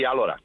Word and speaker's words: Álora: Tú eres de Álora, ¿lo he dicho bien? – Sí, Álora.